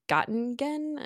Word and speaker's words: Göttingen: And then he actually has his PhD from the University of Göttingen, I don't know if I'm saying that right, in Germany. – Sounds right to me.